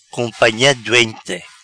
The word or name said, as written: Companha Doente